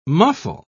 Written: muffle

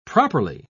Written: properly

